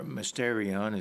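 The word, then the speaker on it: mysterion (μυστήριον): And it's a name that is a mystery, from the Greek word mysterion, or mysterion is actually how it would be pronounced more correctly in the Greek.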